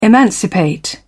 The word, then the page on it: emancipate: British English